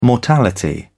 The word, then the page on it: mortality: British English